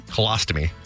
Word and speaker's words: colostomy: Colonostomy bag. – How do you say it again? – Colostomy.